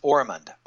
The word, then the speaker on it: Ormond: My first name is Kevin, but it's spelled C-E-V-I-N. I'm Kevin with a C, okay? Last name is Ormond, O-R-M-O-N-D. If you're familiar with Osmond, it's just like that, only with an R instead of an S.